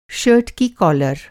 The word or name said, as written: कालर